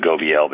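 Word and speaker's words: Gobeille: I think you pronounce it Gobiel, but you can tell me,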